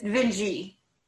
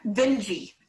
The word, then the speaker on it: Vinge: Vinge or Vince? – Vinge. wait. There is. There's definitely. The. Okay, wait. – Vinge. – You're laughing, but that is the last name. It's spelled phonetically to the side, Vinge.